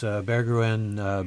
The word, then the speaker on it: Berggruen: And let's not forget the two Nicholases, Nicholas Berggruen, I think is actually how he pronounces his name, and Nicholas Pritzker.